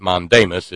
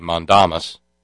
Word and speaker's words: mandamus: We've mispronounced it mandamus. It would actually be mandamus.